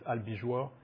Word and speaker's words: Albigeois: So the Albigensians, difficult for me, in French it's Albigeois, easier.